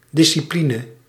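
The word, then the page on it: предмет: /prʲɪd.ˈmʲet/; Russian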